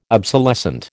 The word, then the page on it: obsolescent: American English